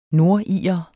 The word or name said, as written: nordirer